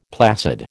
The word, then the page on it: placid: American English